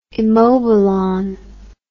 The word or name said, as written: immobilon